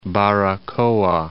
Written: Baracoa

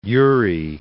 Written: Urey

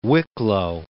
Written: Wicklow

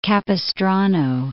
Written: Capistrano

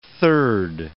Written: third

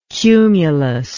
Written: cumulus

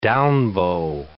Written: down-bow

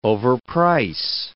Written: overprice

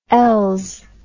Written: els

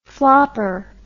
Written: flopper

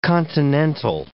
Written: continental

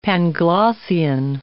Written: Panglossian

